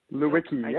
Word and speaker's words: Lewicki: Lewicki, yeah. Just like it sounds. – Lewicki. – L-E-W. – Oh, good. – L-E-W. Lewicki, Ken Lewicki.